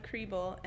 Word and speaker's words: Kriebel: It's my name, Marcella Creeble, and that's also Twitter.